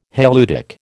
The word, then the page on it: halieutic: American English